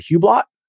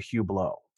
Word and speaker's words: Hublot: A Hublot? And he goes, no, it's a Hublot.